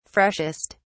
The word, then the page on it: freshest: British English